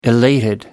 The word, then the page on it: elated: American English